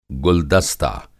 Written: गुलदस्ता

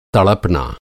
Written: तड़पना

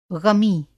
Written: ग़मी

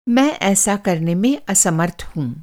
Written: असमर्थ

